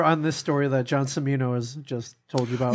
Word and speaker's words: Cimino: It's actually Cimino. – Cimino, sorry. John Cimino. – Michael Cimino is the director. – Okay, my fault. – Yeah, the director. – It is. – The correct pronunciation is Cimino.